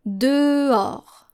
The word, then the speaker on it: dehors: You probably have learned this word if you still practice reading French and practice writing as well, but you are very tempted to pronounce the H in this word, especially native English speakers. And this is how we spell this word. D-E-H-O-R-S. Encore une fois, D-E-H-O-R-S.